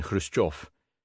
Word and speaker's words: Khrushchev: KH, as in Khrushchev, sounds like CH in Loch.